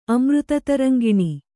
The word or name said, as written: ಅಮೃತತರಂಗಿಣಿ